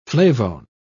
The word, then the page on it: flavone: British English